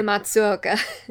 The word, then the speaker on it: Matsuoka: In Japanese, was voiced by Yoshitsugu Matsukoda. God, he's got two really difficult to pronounce names. – If you want to correct me? Yoshitsugu Matsuoka.